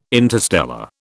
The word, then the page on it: interstellar: British English